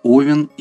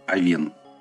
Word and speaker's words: Овен: Как правильно называть это созвездие? Овен или Овен? Словари э, русского языка, словари ударений – нам разрешают ставить ударение и туда, и туда. Выбор ударения зависит от того, кто называет это созвездие. Мне больше нравится ударение на первом слоге.